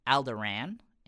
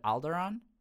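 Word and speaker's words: Alderaan: chief like bodyguard he pronounces it as Alderan instead of Alderon. And that did that never bothered me because I think that makes total sense. People if you've never heard it pronounced before, like if I if I hadn't heard it pronounced like in the original trilogy, I probably would pronounce it wrong as well.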